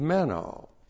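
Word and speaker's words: meno (μένω): is meno. M-E-N long O. Meno.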